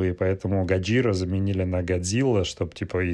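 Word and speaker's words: Гаджира (ゴジラ): Э, по-японски оно произносится как «Гаджира» а не Годзилла.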